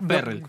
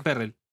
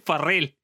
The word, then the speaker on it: Ferrell: Ferrell, Ferrell. Claro, yo siempre me confundo, nunca sé cómo pronunciarlo porque me confundo con el negrito que cantaba en Nerd. Em, Ese es Farrell. bueno bueno, parecido, che. – Bueno, pero este eh, es con E, se escribe con E, Ferrell.